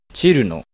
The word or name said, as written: Cirno